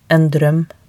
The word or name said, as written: en droem